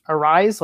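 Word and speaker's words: Arraez: They have speedy players like Buxton, and I believe his last name is pronounced Arise, like Luis Arise.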